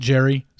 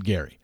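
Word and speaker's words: Gerry: But I understood it because when you see G-E-R-R-Y, I don't know. Some people pronounce it Jerry. Some people pronounce it Gary. He pronounces it Gary, but I can see why people say Jerry.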